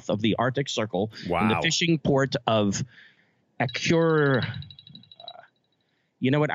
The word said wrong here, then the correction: Akureyri: I had this pronounced properly earlier. Akureyri, Iceland. Akureyri, Iceland.